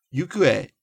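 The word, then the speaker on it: Yukue (行方): Yukue is a special reading. It's not pronounced ho or kata, but e.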